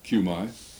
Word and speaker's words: Cumi: Would that I had known Aunt Cumi Woody, C-U-M-I pronounced Cumi.